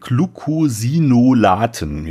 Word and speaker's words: Glucosinolaten: Optimale Wachstumstemperaturen zwischen 20 und 23 Grad Celsius, aber wenn du den Gehalt an Glucosinonaten an Glucosinolaten, jetzt habe ich es richtig ausgesprochen, erhöhen möchtest im Grünkohl 14 bis 17 Grad Celsius.